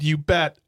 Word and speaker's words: Ubet: There's a place in Wisconsin called You Bet. Oh, You Bet. I'm from You Bet. Is that how it's spelled? – U-B-E-T.